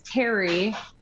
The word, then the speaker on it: Pterry: And his name is Terry with a P. – Of course. Silent P. Has to be. – Yeah. – Terry the pterodactyl. Love it. – P-T-E-R-R-Y.